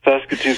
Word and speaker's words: Saskatoon: Saskatoon? Saskatchewan? Sass and Sasquatch? What? Okay. I just go the other way. That's all I know. – Saskatoon, Saskatchewan.